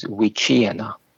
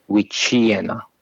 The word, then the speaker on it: Wichiana: And by the way, the pronunciation of the word is Wichiana. – Thank you. – Wichiena. So that CH is a CH.